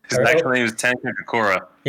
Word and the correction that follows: Takakura: Yes, Takakura.